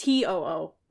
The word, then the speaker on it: TOO: It's pronounced T O O, which is still not good.